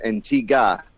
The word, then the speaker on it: Antigua: But as soon as I arrived, I got corrected in the correct pronunciation is Antigua. I guess they don't like to pronounce the U.